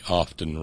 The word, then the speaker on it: often: By the way, often runs through me like a dose of salts. That I know it can go either way, but I was always taught that it's a silent T.